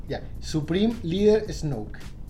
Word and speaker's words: Snoke: Como nieve, pero con K E al final. – Como snorkel, pero no. – Snoke. Es como Snoke.